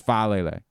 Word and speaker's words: Faalele: Remind me every day we're going to practice Faalele. Faalele. – Faalele. – It's like there are two syllables, but they run right into each other.